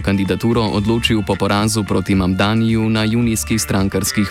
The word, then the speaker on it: Mamdani: And furthermore, the name is Mamdani. Mamdani. You should learn how to say it because we got to get it right.